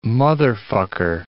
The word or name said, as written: motherfucker